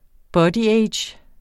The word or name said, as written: bodyage